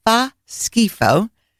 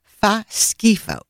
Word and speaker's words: fa schifo: Fa schifo, 2 words, spelled F-A, second word, S-C-H-I-F-O. Fa schifo.